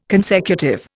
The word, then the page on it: consecutive: Indian